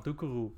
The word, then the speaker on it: Doucouré: Doucouré, I still can't pronounce French names. In Dutch, we call him Doucourou. All you Dutchies will understand this one.